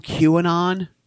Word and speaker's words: QAnon: Well, no, you're right because, you know, it's – dude, this whole QAnon – am I saying it right? – Yeah.